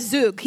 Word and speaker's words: Zug: And Doug said Zug. He's like, Zoo with a G. Yeah. It's very easy. I was like, Zug.